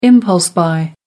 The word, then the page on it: impulse-buy: American English